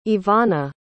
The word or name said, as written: Evonna